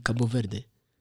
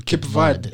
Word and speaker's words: Cabo Verde: The smallest must be Cabo Verde. Or as other people call Cape Verde. – Hey, I think Cabo, I don't know what. – Yeah, that's how it's pronounced in Portuguese.